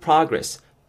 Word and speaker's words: progress: 발음하실 때 주의하실 점은요. I'm making good progress. progress. progress. 이 부분 신경 쓰시면서 저 따라해 보시면 좋겠습니다.